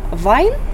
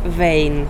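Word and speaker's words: Wein: Например, вино по-немецки это вайн, по-австрийски вейн. Ну, то есть немножко по-другому произносится, определенные но в общем и в целом это тот самый немецкий язык.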